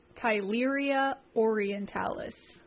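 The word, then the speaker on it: Theileria orientalis: Tularea Orientalis. Am I close? – I think it was pronounced Tularea Orientalis. – You nailed it.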